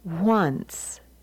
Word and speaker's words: once: Dance, chance, fence, hence, and the next column with the N-C-E, the nts, it's mince, since, dunce, and the word in the box is pronounced once. And now the nts with the N-S-E spelling, and the words are dense, sense, tense, and rinse.